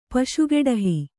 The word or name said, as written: ಪಶುಗೆಡಹಿ